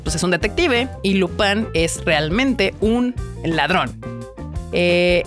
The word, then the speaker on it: Lupin: Si usted es fan del anime, le debe de sonar el nombre, tal vez Lupin o Lupin, porque el nombre se escribe Lupin, pero al ser francesa se pronuncia Lupin.